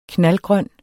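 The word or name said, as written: KNALDGRØN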